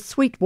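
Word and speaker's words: Suite: Louisa Trewatha is an Australian composer, Victorian, and her Sweet Water, which is a play on spelling, which you can't see, but it's S-U-I-T-E, but pronounced, of course, as sweet.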